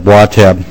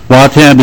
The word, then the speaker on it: Watab: In the center of the map is Watab Township, Watab, that's how it's pronounced. Watab is unique.